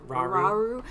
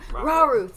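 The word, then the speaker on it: Rauru: Raru? – Raru, thank you.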